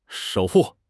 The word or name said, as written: 守护